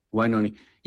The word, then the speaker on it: Wynonie: And so there's people like, is it Wynonie Harris or Wynonie? – Yeah. – How do you pronounce his first name? – Wynonie.